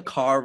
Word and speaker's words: Kerr: I know the Carr rhymes with star thing, but I still manage to say Kerr sometimes.